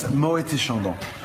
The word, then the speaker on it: Moët et Chandon: It's Moët et Chandon, but if you put the two together, it's Moët et Chandon. So the T, it's pronounced if you put the two names together.